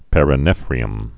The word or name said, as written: perinephria